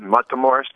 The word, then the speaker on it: Matamoros: And let me start with, it's called the City of Children in Matamoros, Mexico. Did I pronounce that right? – That's correct. The City of Children, Matamoros, Mexico.